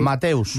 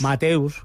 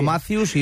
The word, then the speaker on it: Mateus: Sí, Mateus. – Mateus. – Yo claro. Porque lo he buscado, ¿eh? No sabía si es Mateus no o Matthews, y lo he buscado.